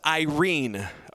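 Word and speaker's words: Irene (εἰρήνη): The word peace in Luke, the way that Luke is writing it here in his research paper, is a word in Greek pronounced Irene, okay?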